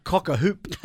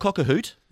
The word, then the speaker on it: cock-a-hoop: Yeah, I'm reluctant to use this word because I actually don't know how to say it, but I'm cock-a-hoop. – Oh, good. You're learning. – Is that right? – Absolutely right, yes. – Cock-a-hoop? – Yep, not cock-a-hoot, as you've said once, but it's cock-a-hoop.